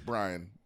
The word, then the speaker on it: Brian: It's Brian, not Brain.